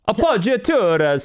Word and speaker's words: appoggiaturas: Billy Joe Armstrong, the vocalist, guitarist, uses a lot of appoggiaturas. Appoggiaturas. Sorry, my Italian half of the family wants me to say it correctly. Appoggiaturas, which is a kind of non-chord melodic tone, a kind of melodic device that we're not talking about today.